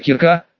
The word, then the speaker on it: Кирка: После того, как местную девочку, которая носила, как удалось понять, забавное имя Кирка, с ударением на «и» подлечили, ее тут же принялись обучать в школе.